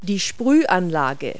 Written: sprinkle

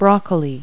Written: broccoli

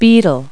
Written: beetle

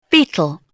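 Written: beetle

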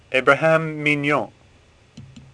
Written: Mignon

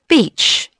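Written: beach

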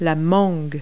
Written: mango

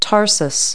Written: tarsus

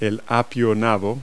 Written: celeriac

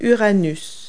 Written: uranus

